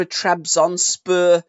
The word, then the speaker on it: Trabzonspor: Finally, Daniel Sturridge surprised that he signed for Trabzonspor, and I've said that right.